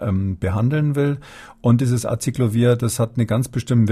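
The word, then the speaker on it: Aciclovir: Frau Frau Schuritz aus Fulda fragt per Mail, ob der Wirkstoff Acyclovir die Virusvermehrung hemmen könnte. – Habe ich das jetzt richtig ausgesprochen? Ja, ja, Acyclovir.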